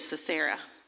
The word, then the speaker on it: Sisera: Let me say that again, Sisera. Pronounce that right.